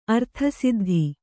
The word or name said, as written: ಅರ್ಥಸಿದ್ಧಿ